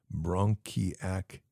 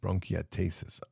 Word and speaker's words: bronchiectasis: I don't know if I said this bronchiet. Oh, it's okay. Bronchiactasis. Bronchiectasis. I have never heard of this. So it's what it is bronchiectasis.